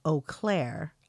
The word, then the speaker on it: Eau Claire: I live in West Central Wisconsin near the Eau de Claire area, pronounced Eau Claire. Oh, why did I say Oda? It's Eau Claire.